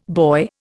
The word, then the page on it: boy: Indian